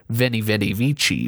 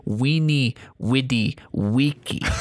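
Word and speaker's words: veni, vidi, vici: And some historians say that would be pronounced not Vinny, Vidi, Vici, but Weenie, Widi, Weeky.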